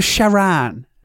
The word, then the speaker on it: Sharan: Yeah, the Volkswagen Sharon. Well, Sharan.